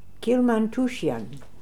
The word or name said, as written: Kilmantooshian